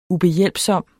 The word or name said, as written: ubehjælpsom